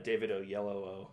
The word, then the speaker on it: Oyelowo: The that was like, yeah, David Oyelowo, which is how you say his name.